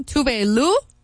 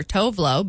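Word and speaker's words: Tove Lo: And speaking of new music, I just found out, for one, her name, Tuve Lu. I've always called her Tovlo, but she says that's also acceptable because that's how, well, most Americans say it.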